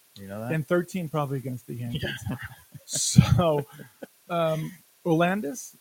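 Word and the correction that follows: Aroldis: is that how you pronounce it? – Oraldis, excuse me.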